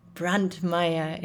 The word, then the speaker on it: Brantmeyer: Yes, I pronounce his last name the German way. He probably pronounces it differently, but Brantmeyer it is.